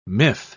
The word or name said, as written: miff